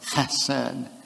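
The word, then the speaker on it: chesed (חסד): You have to kind of clear your throat when you say it, okay? If you're going to say it properly, chesed.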